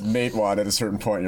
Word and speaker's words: Matewan: Matewan.